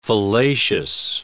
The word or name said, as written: fallacious